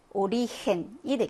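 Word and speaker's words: origen: 자, 마지막 5번 보시면 얘는 오리헨이 되겠죠. 애와 만나서 강한 히읗 발음이 나겠네요.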